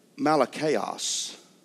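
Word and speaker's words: malakos: This homosexual, the pronunciation is Malachaos. Boy, that says right there, Malachaos.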